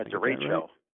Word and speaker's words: derecho: Last year there was an event that I had never heard of in Iowa, and I'm going to try not to mispronounce it, a derecho. – Yeah, derecho. – Derecho, close.